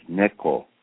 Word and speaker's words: Neko: N-I-K-O, which they were mispronouncing, which for Japanese, it cat in Japanese is Neko, N-E-K-O, but they were mispronouncing it, but everyone knew her as Niko.